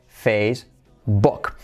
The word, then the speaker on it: Facebook: La número uno, number one, la más conocida, la que más usuarios tiene, que es, como decimos en español, Facebook, pero ¿cómo se dice realmente Facebook? Tiene la letra semiclave U como O, precisamente porque es muy recurrente el decir book y queremos acabar con eso para salvar el inglés, que todo el mundo diga book.